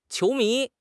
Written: qiu2 mi2

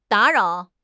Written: da3 rao3

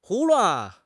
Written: 胡乱

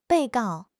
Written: 被告

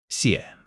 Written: xie3

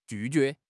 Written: ju4 jue2

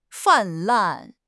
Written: 泛滥

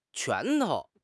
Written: 拳头

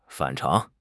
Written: 反常

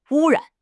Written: wu1 ran3

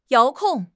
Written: 遥控

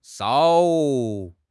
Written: shao3

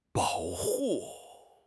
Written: bao3 hu4

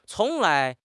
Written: cong2 lai2

